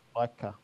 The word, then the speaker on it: Leica: Now, I know I'm not going to pronounce this right, but it's a leash. Leica. What is it, sorry? – Leica. – Leica. – Leica. – Leica. And, yeah. – Like a glove?